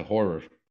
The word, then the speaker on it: horror: Yeah, the reason why horror is spelled the way that it is so you hit the R's in the middle harder.